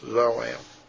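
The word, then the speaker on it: za'am: And it is, in Strong's, it is Zoam, the way it's pronounced, Zoam.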